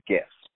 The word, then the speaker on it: GIF: So I know that the creator of the format and the file format says that it's a soft J. I don't care. It's always a hard J, or a hard G in my mind. It's GIF.